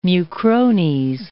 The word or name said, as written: mucrones